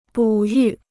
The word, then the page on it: 哺育: bǔ yù